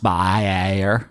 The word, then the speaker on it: Bayer: Vanessa Bayer. – It's pronounced Bayer, actually.